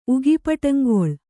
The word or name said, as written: ಉಗಿಪಟಂಗೊಳ್